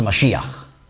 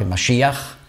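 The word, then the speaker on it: Mashiach (מָשִׁיחַ): Oder Mashiach. Das ist die hebräische Aussprache Mashiach, die griechische Umschrift dieses Wortes Messias. Aber nicht Übersetzung, sondern Umschrift. Die Griechen konnten nicht Sch sagen.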